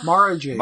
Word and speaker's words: Mara Jade: Is it Mara? – Mara Jade. – Mara Jade. Mara Jade. Okay, then.